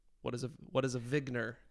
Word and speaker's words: Wigner: I pronounce it Wigner crystal, but we're not very uniform about that. Eugene Wigner's been dead for a long time, so he's not around to correct us anymore.